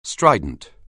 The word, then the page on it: strident: anglais